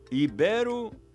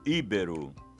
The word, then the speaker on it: ibero: Como você pronuncia filantropo ou filântropo? Ibero ou íbero? Avaro ou ávaro? Todas essas palavras são paroxítonas e assim devem ser pronunciadas filantropo, ibero, avaro.